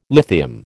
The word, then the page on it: lithium: American English